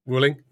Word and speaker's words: Wuling: And that's, I don't know how to pronounce it now, Wuling or Wuling. Yeah. – Wuling.